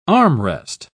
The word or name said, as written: armrest